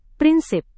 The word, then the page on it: prinsip: Indonesian